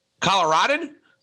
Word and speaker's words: Coloradan: And how do you say that? Coloradan? – Coloradan, yeah.